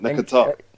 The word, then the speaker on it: Nketiah: The second part is there is a bit of me which is making me think Arteta probably does need to throw Nketiah in now. How you pronounce it? – Nketiah.